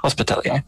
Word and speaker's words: Hospitalier: Is that the correct pronunciation? Yeah. Hospitalier.